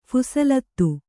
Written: ಫೈಸಲಾತಿ

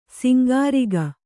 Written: ಸೊಂಕುರೋಗ

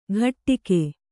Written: ಘಟ್ಟಿಕೆ

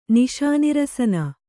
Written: ನಿಶಾನಿರಸನ

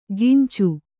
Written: ಗಿಂಚು

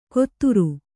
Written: ಕೊತ್ತುರು

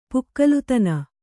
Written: ಪುಕ್ಕಲುತನ

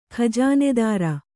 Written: ಖಜೀನದಾರಿ

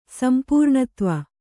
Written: ಸಂಪೂರ್ಣತ್ವ

